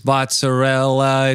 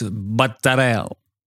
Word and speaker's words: mozzarella: He said, it's called, don't fucking come in here and call this mozzarella, it's mattarella.